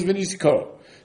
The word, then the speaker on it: v'nisko: The word is written as if it says v'niska. And it's pronounced as if it says v'nisko. So that tells me the niska, referring to the feminine mincha, is the same as the nisko of the yayin.